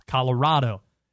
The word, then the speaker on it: Colorado: And they're saying, you're saying the name of our state wrong. I'm like, Colorado. No. Yes. That's how it's said. It's Colorado. It's Colorado.